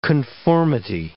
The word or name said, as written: conformity